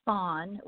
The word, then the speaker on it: Fon: The biggest language is called Fon, which is spelled F as in Frank, O-N, Fon.